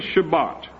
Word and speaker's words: Shabbat: I think originally it's pronounced something like Shabbat, but you hear most Jews today saying Shabbat. So the word is Shabbat.